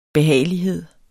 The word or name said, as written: behagelighed